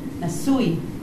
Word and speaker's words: Nesui: But you know we have ui at the end, so you need to pronounce the yod. Ui. Nesui.